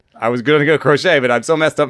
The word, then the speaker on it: Crochet: How do you say the guy's name that sits 100 from the left side? – Garrett Crochet. – Crochet. Nice. Better than the other one, which rhymes with rocket.